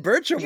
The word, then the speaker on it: Burcham: Burcham.